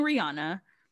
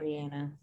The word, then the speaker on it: Rihanna: Like, y'all let him get away with hitting Rihanna I'm going to say her name right just automatically one day. – Rihanna. – Sorry, Rihanna.